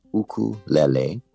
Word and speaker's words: ukulele: It's pronounced ukulele, and it's two Native Hawaiian words, uku, which means flea, and lele, which means jumping. So it's the direct translation is jumping flea, ukulele.